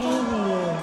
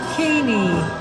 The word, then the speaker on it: Kanye: Kanye West. – Vad sa du? Säg hans namn Kaney.